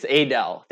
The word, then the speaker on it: Adel: I think it's pronounced Adele. I am relatively new to Iowa, so I'm sure the longtime Iowans on this podcast can probably correct me on that pronunciation. But okay, it's Adele.